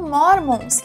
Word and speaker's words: Mormon: E o que eu queria falar, na verdade, é que eram mormons, que em inglês é mormon. Você viu que é parecido? Morons e moron. Again, Mormon. Você viu que é parecido a pronúncia, mas não é a mesma.